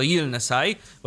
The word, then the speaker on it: Yilnesye: Tak, że tak to powinno się wymawiać, natomiast pisze się to Y-I-L-N-E-S-Y-E. Więc załóżmy, że to Gilnesai powinno się wymawiać.